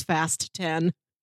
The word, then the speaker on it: Fast X: and the invitation specified, you pronounce this Fast 10.